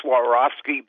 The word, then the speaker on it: Swarovski: We talked a couple months ago, and I got my Swarovski binocular finally.